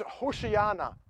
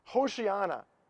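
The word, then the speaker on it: Hoshiana (הושיעה נא): The Hebrew word is Hoshiana. Hoshiana.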